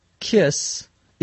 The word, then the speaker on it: KISS: So you may hear someone say, I need this ASAP, A-S-A-P. In this case, KISS is pronounced like the word kiss. A kiss is when you touch your lips to someone else.